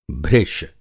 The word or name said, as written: भृश